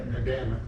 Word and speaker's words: Adamic: How do you say Adamic. That properly? Adamic? – Adamic.